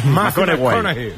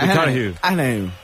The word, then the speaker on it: McConaughey: ¿Mateo McConaughey? – Sí. – Es un futbolero, le gusta. Hay que ir corrigiendo la pronunciación. – McConaughey. Mateo McConaughey.